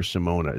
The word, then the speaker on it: Simona: There's something pretentious about her name is spelled Simone, but they're calling her Simona. Is that some kind of she affectation that this idiot is insisting she goes by, or is that just Wiggy being 79? – All signs point to yes on the pretentious level. I don't know. I was spelling it wrong throughout the entire thread we did because I had no clue. But well, yeah, that's fine. Simona, I mean, but it's not. It's spelled Simone.